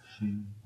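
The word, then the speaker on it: Hsing: H-S-I-N-G, I think it's the, Xing. – Yeah, Xing, Sing.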